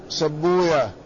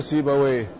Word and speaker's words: سيبويه: قال: وصاحب العين في اللغة وشيخ سبوية، هكذا ينطقه المحدثون، لا يقولون سيبويه، لأن المحدثين لا ينطقون بويه، ذلك أن ويه اسم من أسماء الشياطين عندهم، وهم حريصون على أن لا يتعاملوا مع الشياطين، فكل ما جاء على هذا الوزن هكذا ينطقونه، فهم يقولون في إسحاق ابن راهوية، وفي صاحب الخليل سبوية، وهكذا نفطوية، وهكذا كل ما جاء على هذا الوزن.